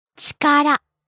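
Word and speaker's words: ちから: Like by itself, you pronounce it differently, right? – はい. By itself, we say chikara.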